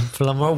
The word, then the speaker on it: plumber: A plumber will fix your water supply. It's fontanero, fontanera. – And it has a silent B in the middle. It's P-L-U-M-B-E-R, plumber.